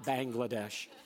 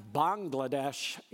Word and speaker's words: Bangladesh: We have to get the right pronunciation, Pastor. It's not Bangladesh. Bangladesh in Bengali means land of frogs. Bangladesh is the land of the Bangla people and Bangla language, Bengali.